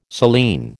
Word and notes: saline: American English

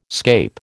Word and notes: scape: American English

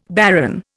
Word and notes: baron: Indian

